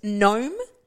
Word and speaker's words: gnome: Gnome. – Knit. – So knit and gnome. So now we're going from silent K's to silent G's.